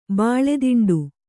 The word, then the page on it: ಬಾಳೆದಿಂಡು: Kannada